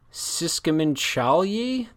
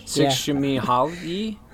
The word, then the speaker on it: Csikszentmihalyi: Mihaly Csikszentmihalyi. This is a name of the person. Ooh, I mispronounced that. Sorry. I had not prepared this. – Csikszentmihalyi.